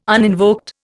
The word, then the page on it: uninvoked: Indian